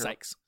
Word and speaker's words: Sykes: how do you say her? – Sykes.